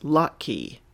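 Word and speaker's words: Lachie: The next podcast I have to tell you about is Open Up with, I'm not sure how to pronounce this first name. It's L-A-C-H-I-E. Lakey? Lachey? La-dish. Hold on and let me look up that pronunciation. Okay, so Google is your friend. Google is always your friend when you're not sure how to pronounce things. This is pronounced Lockheed.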